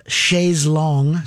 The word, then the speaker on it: chaise longue: And that one's called Chaise Longue. – Chaise Longue.